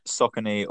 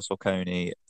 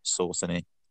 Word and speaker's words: Saucony: Yeah, so Saucony or Sauconi are two acceptable pronunciations, just not Saucony.